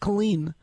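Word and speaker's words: Culzean: C-U-L... – Right. – Z-E-A-N, and the Z is silent, pronounced Killeen, where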